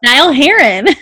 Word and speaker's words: Niall Horan: Niall Horan.